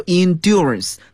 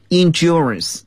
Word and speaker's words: endurance: 体育运动可以增强人的耐力 endurance。在英国英语中呢我们可以读作 endurance，但是在美国英语中呢我们发音就比较浊化一点了， endurance。